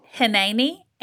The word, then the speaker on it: hineni: That word "hineni," H I N E N I. – Am I saying that correctly? – Yes.